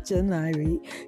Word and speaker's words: Yoniery: I'm sure if you're on Instagram and you look up Y-O-N-I-E-R-Y, pronounced Janairi, you'll see his video.